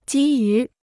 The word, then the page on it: 基于: jī yú